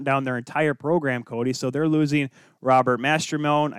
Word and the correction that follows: Mastrosimone: I Mastrosimone, sorry, I said that wrong, Alex Vlasic, and Drew Commesso.